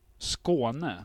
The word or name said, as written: Skåne